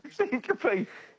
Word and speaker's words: syncope: Syncope. So the word is syncope, which is spelt S-Y-N-C-O-P-E, is the medical name for what condition?